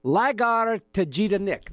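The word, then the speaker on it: Lagartija Nick: Before that, a reissue of Bauhaus' greatest hits, Larja Tajita Nick. Lagarja Jida Nick. I wish I could pronounce that. Let's do it right. Lagarja Jida Nick. There we go.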